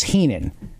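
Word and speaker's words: Henon: So first of all, Councilman Bobby Heenan, his name is missing an E. It's H-E-N-O-N, but it's pronounced Heenan.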